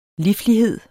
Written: liflighed

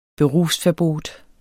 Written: berufsverbot

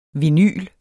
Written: VINYLET

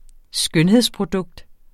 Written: skønhedsprodukt

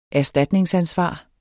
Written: erstatningsansvar

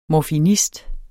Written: morfinist

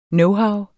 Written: knowhow

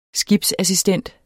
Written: skibsassistent

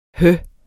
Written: hø

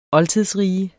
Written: oldtidsriget

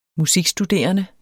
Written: musikstuderende

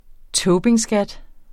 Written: tobinskat

